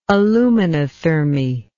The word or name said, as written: aluminothermy